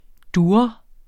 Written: durre

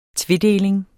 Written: tvedeling

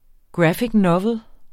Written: roman

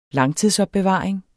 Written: langtidsopbevaring

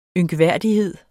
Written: ynkværdighed

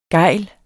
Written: gejl

